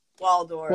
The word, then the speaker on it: Waldorf: Wardorf is another. Wardorf, I have to say it wrong. I think I'm saying Waldorf? – Waldorf. – Waldorf? Okay, yeah. – Waldorf, yeah. Waldorf.